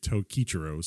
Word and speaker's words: Tokichiro: you come in contact uh with Tokichiro or how do you say it? Tokichiro, sorry.